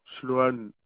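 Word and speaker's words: Slohan: Sloane.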